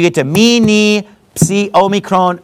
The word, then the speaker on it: Omicron: Omicron pronounced in English Omicron, but in Greek it's pronounced Omicron, okay?